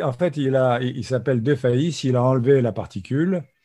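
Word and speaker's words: Defays: Parlons un peu, si tu veux bien, de Pierre Richard, Maurice, Charles, Léopold, De Defesse. Oui, enfin, ça se prononce de Faïs. Ah, de Faïs, pardon, parce qu'il aurait dû garder son vrai nom, c'est plus drôle que Pierre Richard.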